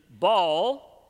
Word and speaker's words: Baal: Baal, sometimes pronounced Baal. Probably the proper pronunciation is Baal.